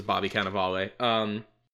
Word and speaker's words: Bobby Cannavale: Also, it took me forever to pronounce, because it's spelled Bobby Cannavale. – Yeah. – And that's how I pronounced it for a while, but before I heard it being pronounced as Bobby Cannavale.